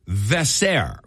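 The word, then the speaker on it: Veesaar: I don't know if this is Henry, but usually when it's spelled H-E-N-R-I. – It's Henri. – It's Henri Vesser. V-E-E-V-E-E-S-A-A-R.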